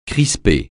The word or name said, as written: crisper